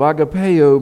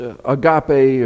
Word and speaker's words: agape: Agapeo may be a better way to say it. It's the Greek term that you've heard pronounced agape or agape.